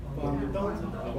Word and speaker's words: Guangdong: One of those, and you're going to have to help me pronounce this, one of those is in Guangdong? – Guangdong. – Guangdong? – Yes.